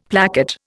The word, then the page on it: placket: Indian